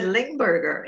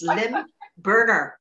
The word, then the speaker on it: Lymburner: I said Lymburner. It's Lymburner.